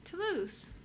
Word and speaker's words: Toulouse: How do you pronounce it? – Toulouse. – Toulouse, because the burger.